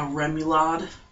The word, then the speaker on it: remoulade: A remoulade.